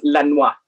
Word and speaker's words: Lenoir: And can you say that again, please? Because I've always just gone Daniel Lenoir. And like, so how's it pronounced? That's I've never heard it pronounced like that. – So his first name would be Daniel. – Uh-huh. – And then his last name is pronounced Lenoir.